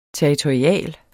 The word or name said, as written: territorial